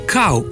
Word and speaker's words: kal: Odgovor na vprašanje deloma ponujajo priročniki, in sicer slovenski pravopis, ki pravi: kav v pomenu mlaka bi dejansko brali kav, medtem ko sta v pomenu zelena kal, kal krompirja, klica in podobno možni obe obliki izgovorjave, kal in kav.